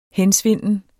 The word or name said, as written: hensvinden